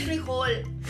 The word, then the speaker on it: frijol: Pero la RAE, que es la Real Academia de la Lengua Española, dice que se puede de ambas maneras con un acento en la primera I o sin acento. Así que no hay por qué pelear, muchachos. Entonces. Es frijol.